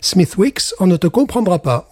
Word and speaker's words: Smithwick's: Smithwicks, c'est la prononciation parce qu'évidemment nous sommes en Irlande. Donc, euh, faut pas sortir sa très belle prononciation euh, anglaise à ce moment-là parce que vous n'êtes carrément pas compris.